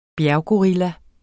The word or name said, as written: bjerggorilla